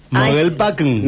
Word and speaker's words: Mogelpackung: En alemán se pronuncia así. Mogelpackung.